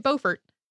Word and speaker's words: Beaufort: B-E-A-U-F-O-R-T, probably you say it Beaufort. Please do not write to us if you say that we said it wrong. In South Carolina, for some reason, it is Beaufort, even though most of these places that most other people say Beaufort are named after the same person who pronounced it Beaufort.